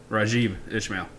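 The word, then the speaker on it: Raghib Ismail: And you don't even know how to pronounce his real name. – Rajib Ishmael. – Rajib Ishmael? – I think so. – Yeah. Rajib.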